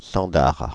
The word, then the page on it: cendar: France (Île-de-France); /sɑ̃.daʁ/